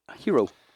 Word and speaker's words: Gyro: How do you say Gyro? – Hero.